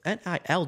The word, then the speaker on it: NIL: And, you know, the whole new NIA deals. I don't know why that acronym is so hard for me to say. NIA deal. NIL deals. Jesus, I don't even say it wrong.